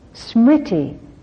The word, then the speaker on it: Smriti: Smriti is the wonderful Sanskrit word for it.